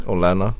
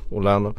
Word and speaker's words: Olenna: Olenna. – Olenna.